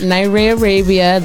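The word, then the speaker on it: Nyrae Arabia: You can find me everywhere at Naira Arabia.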